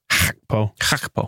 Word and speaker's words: Gakpo: I'm just going to say the important stuff is that he's known as Cody Gakpo. – Gakpo. – That's how you pronounce it properly in Dutch, I think.